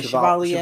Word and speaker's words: Chevalier: Chevalier? – Yes. – Yeah, Chevalier.